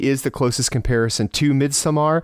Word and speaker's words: Midsommar: That is how it's pronounced. – But it's Midsommar.